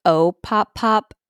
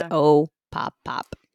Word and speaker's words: Opopop: I'm pretty sure it's Opopop, but for a while I was like Opopop, and I looked up videos, and basically they're like, we don't care how you pronounce it as long as you remember it and love us. Yeah. So, but it's Opopop.